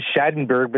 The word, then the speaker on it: Schadenberg: Okay, my name is Schadenberg, but that's fine. There's different pronunciations. If you're in Germany, you'd pronounce it differently, but that's fine because my father was German.